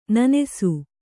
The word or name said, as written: ನೆನಸು